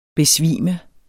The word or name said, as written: BESVIMER